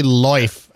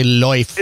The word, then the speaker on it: Lauf: Mostly I've ridden carbon forks and now the Lauf. How do you even pronounce it properly? – I think they say Loif. Actually, I believe it means leaf. – It means leaf. – But they say Loif.